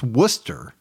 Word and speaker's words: Worcester: I believe it's pronounced Worcester. – Yeah. – Massachusetts. – I know where it's at. – Even though it's spelled more like Worcestershire sauce.